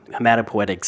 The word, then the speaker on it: hematopoietic: starting from hematopoietic stem cells. Did I pronounce that right? – Yes.